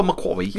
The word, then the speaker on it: McQuarrie: I can't say his name. Christopher McQuarrie. – Yeah, that's exactly right. That's how he says it.